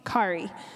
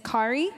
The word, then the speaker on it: Kauri: Something of mine that I get from my great-grandmother, and that is my name, Kari. If there's anyone here who does not know me, I am the youth pastor and children's pastor here, and my name is Kari, and it is spelled K-A-U-R-I.